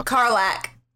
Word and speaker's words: Karlach: Lulu versus, how do you say it? Is it Karlach? Karlach? – Karlach.